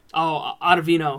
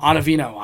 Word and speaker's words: Ottavino: Ottavino. – Ottavino.